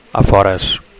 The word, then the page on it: afores: Catalan